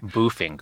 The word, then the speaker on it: boofing: I don't know what boffing or devil's triangle mean. I'm sorry. – Boofing, Greg. – Is that the way you pronounce it? – Goodness.